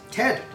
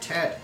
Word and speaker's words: Ted: Ted. I'm Ted. It's pronounced Ted.